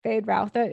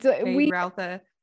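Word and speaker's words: Feyd-Rautha: Fade Rautha, is that how you say it? – Yeah. – It's Fade Rautha Harkonnen.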